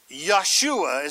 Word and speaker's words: Yeshua: Yeshua is how you pronounce it in Hebrew.